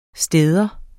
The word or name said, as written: stad